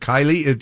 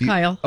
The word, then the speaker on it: Kyle Richards: Did I say that right? I said Kyle Richards. Is it Kylie? No, it's Kyle.